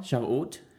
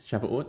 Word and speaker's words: Shavuot: Right, Shavuot. – Sorry, can you pronounce that again? – Shavuot. Shavuot, that's S-H-A-V-U-O-T.